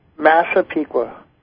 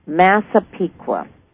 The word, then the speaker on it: Massapequa: Massapequa. – Massapequa.